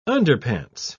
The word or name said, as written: underpants